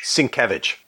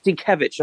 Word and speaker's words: Sienkiewicz: It is, and I always mess up his pronunciation, Bill Sienkiewicz? – Sienkiewicz. – Sienkiewicz, oh my God.